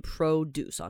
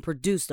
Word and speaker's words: produce: But oftentimes people would choose fresh produce. Fresh produce 就是新鲜的农作物嘛，produce 其实就是农作物，就是蔬菜类。但你要注意这个字，你一定要念 produce 啊，你要注意它的重音，你要注意它的 o 是怎么发音。如果你念成 produce 的话，那就会变成动词，就是另外一个字了。